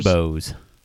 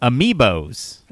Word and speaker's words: Amiibos: From Ambos to Skylanders. – Amiibos. – Say it one more time. – Amiibos. – Amiibos. From Amiibos to Skylanders, game companies have led the toys to life charge to translate real world objects into digital worlds.